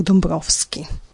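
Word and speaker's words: Dąbrowski: Hodiau eh, mi volus rememorigi la silueton de unu inter iom forgesitai e pioniroi de la Esperanto movado, pastron Aleksandras Dambrauskas, au lau la pola prononco Alexander Dombrovski.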